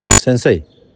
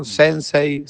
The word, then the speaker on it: Sensei: Pero no, yo digo en la manera de pronunciarlo, ¿no? Sensei, sensei. – Sí, cualquiera de los dos es aceptable.